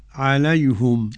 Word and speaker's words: عليهم: عليهم أولا ضم الهاء يعني قبلها ياء لينية لكل من حمزة رضي الله عنه ويعقوب. نعم. الحضرمي تنطق كيف فضيلة الشيخ؟ – عليهم.